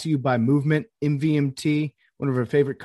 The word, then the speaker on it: MVMT: MVMT, pronounced Movement, grew into one of the fastest growing watch brands, shipping to over 160 countries across the globe.